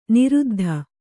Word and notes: ನಿರುದ್ಧ: niruddha

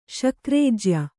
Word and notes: ಶಕ್ರೇಜ್ಯ: Kannada

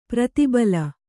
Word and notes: ಪ್ರತಿಬಲ: prati bala

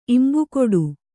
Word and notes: ಇಂಬುಕೊಡು: imbukoḍu

